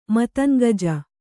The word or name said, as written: ಮತಂಗಜ